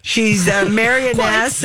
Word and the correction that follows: marchioness: a marchioness. How do you say that? – I believe it is marchioness.